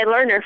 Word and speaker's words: Learner: And I'm joined today by Lerner from Orcas Island, Washington.